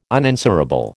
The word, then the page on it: unanswerable: American English